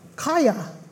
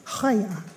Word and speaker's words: chaya: Could you pronounce that lovely word for me again, please? Chaya. Chaya. Chaya. So, a Hebrew word called chaya, that's not how it's spelled.